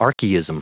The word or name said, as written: archaism